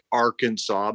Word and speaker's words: Arkansas: It's spelled like Arkansas, but we pronounce it Arkansas, which is how it's spelled.